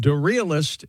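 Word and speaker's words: Da'Realyst: And Darealist is spelled D-A apostrophe R, capital R-E-A-L-Y-S-T, Clark.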